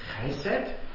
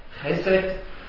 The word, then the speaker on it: Chesed: Also es wieder hier für seine Güte, Chesed. Chesed, das ist eigentlich das große Thema Chesed. Und Chesed, wo der Vater sagt, es kommt zum Kai Leben. Und Zed, also hier wird es mit Zed ausgesprochen, das SD, das ist verwandt mit Sod und Sod ist Geheimnis.